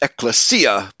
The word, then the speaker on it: ekklesia: And but I think the Greeks actually pronounce it ekklesia, putting the accent on the third syllable.